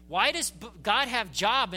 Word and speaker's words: Job: Looks like Job, only we pronounce it Job. One of the great ironies, again, of the English language. It's not Job, it's Job.